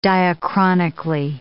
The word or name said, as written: diachronically